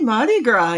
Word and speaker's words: Mardi Gras: I'm probably not doing this properly, but happy Mardi Gras. – Happy Mardi Gras, yes. – Is that how you say it? – Yes, that's how I say it.